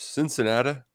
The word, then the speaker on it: Cincinnati: Cincinnati is what you got to call it, though. That's how it's pronounced.